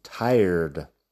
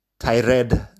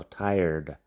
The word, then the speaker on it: tired: Fíjate en la pronunciación, tired. Tired, que no es tired ni nada de eso, tired. La R en medio. Suave, R inglesa, tired.